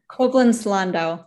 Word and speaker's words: Koblenz-Landau: With the Department of Psychology at the University of Koblenz-Landau. Did I get that pronunciation correct? – Koblenz-Landau.